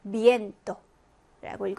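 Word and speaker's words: miento: 그러면 에를 강하게 읽어주시면 되죠. 미엔토라고 읽어주시면 되고 세 번째 단어 보시면 역시 이와 에가 만났습니다.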